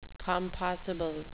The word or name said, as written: compossible